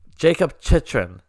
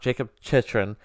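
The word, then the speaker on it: Jakob Chychrun: But just a few minutes after that, on the power play, I'm going to say it wrong again. I just heard it. Jacob Chychrun. There we go. I worked on it. Jacob Chychrun gets his 18th of the year on the power play.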